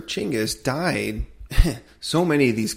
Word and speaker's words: Chinggis: So, Genghis Khan, I think we've all been like enamored with that name Genghis Khan, which we're both calling him Chinggis Khan too, because we did some reading and apparently that's the proper pronunciation.